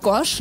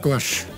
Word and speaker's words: Gars: Jetzt muss ich euch schon fragen, Gars und Gars, spricht man das einmal gleich aus? Sagt ihr auch Gorsch na, oder? – Wir sagen auch Gorsch.